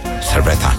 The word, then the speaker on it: cerveza: Dan moet je zeggen, even op je tong bijten en zeggen, cerveza.